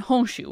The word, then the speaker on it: Honshu: Awaji, Shikoku, Ikikyushu, Tsushima, and Honshu, which is the biggest island.